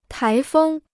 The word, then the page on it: 台风: tái fēng